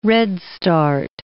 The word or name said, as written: redstart